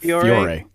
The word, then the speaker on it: Fiore: Could you tell us just for a second about, is it Fiore? – Yeah, that's how you say it. – Fiore.